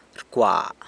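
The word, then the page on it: rcwa: San Lucas Quiaviní Zapotec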